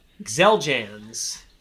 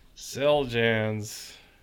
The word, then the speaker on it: Xeljanz: Number 11, Xeljanz. – Xeljanz.